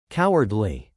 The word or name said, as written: cowardly